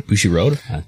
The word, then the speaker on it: Bushiroad: And we will go from Mexico to Japan, and, the finances of, Bushiroad. Did I pronounce that correctly? – Bushiroad? – Okay.